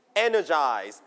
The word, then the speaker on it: energized: นะครับสมุดขึ้นมาจดคำศัพท์นะสมุดขึ้นมาจดคำศัพท์อย่าลืมนะครับไปฝึกมาด้วยนะครับ wave and wave to wave นะครับ two waves okay the first one feeling full of energy like you นะครับ when you want to do something I'm so ready you have like a lot of energy we call energized นะครับ repeat after me energized นักเรียนปลายทางพร้อมกันนะครับ energized นะครับ so you put the stress on the first syllable